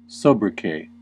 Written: sobriquet

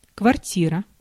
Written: apartment

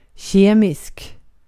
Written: kimyasal

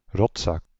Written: fils de pute

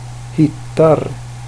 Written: hittar

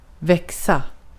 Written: växa

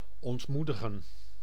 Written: dishearten

